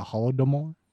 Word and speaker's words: Holodomor: The Holodomor, and how do you pronounce that? The Holodomor? Domor? – Yeah.